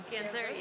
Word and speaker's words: Ganser: from Ganser. Am I pronouncing that correctly? – Ganser, yeah, you are.